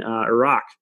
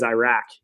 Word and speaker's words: Iraq: So we're in Nepal, India, Somalia, and then Iraq. So, and I got to start saying it right. You know, everybody says Iraq, and I'm like, Iraq. – It's not Iraq.